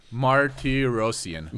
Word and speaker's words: Martirosyan: Martirosyan.